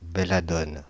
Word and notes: belladone: /be.la.dɔn/

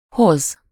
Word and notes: brengen: [bɾǝŋ.ǝː]; /ˈbrɛ.ŋə(n)/

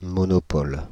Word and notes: monopole: [mo.no.pɔl]; /mɔ.nɔ.pɔl/

